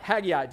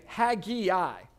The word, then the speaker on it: Haggai: And I told you that the way that we as English speakers pronounce this name doesn't make any sense because we typically say Haggai, right? Haggai. Where does the E come from? If you look at it, it's more like Haggai, right? Is kind of how it reads.